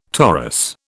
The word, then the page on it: torus: British English